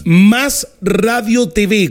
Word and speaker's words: Más Radio TV: Más Radio TV, con acento en la A de Más.